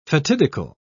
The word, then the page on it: fatidical: British English